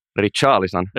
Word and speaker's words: Richarlison: Yksi iso hankinta, mikä Suomessa varmasti kiinnostaa, on tuo Richard Leeson, jos mä näen, vai Richard Leeson, vai miten se lausutaan. – Richarlison.